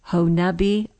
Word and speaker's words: Honobia: Ralph was already guitarist for Kiamichi Christian Men's Clinic in Honubi, Oklahoma. That is how you say that, Mark. It's spelled different. – I looked it up. They say it that way, that the people that live there. – Yeah, H-O-N-O-B-I-A.